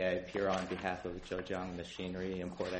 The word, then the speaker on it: Zhejiang: Zhejiang Machinery and Export Corporation versus United States. Tell me what your client's name is. How do you pronounce it? – Zhejiang Machinery Import Export Corporation.